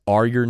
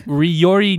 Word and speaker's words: Ryori: on PC is Are Your Ninja. And so I'm just saying. – Okay, I'm going to tell you right now. Everybody keeps pronouncing that gamer tag wrong. It's called Ryori Ninja.